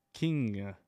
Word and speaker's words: King: Did I say that right? – No. – Fuck. – Do you ever? – No. – Okay. – King. How about that time? Better? – You're good.